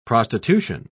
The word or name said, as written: prostitution